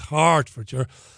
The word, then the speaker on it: Hertfordshire: It's not Hertfordshire. It's Hertfordshire.